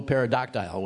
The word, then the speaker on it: pterodactyl: Next, of course, buffalo pterodactyl wings. Okay. I didn't really mean to say that one. Because I don't think you pronounce the P, and I always do.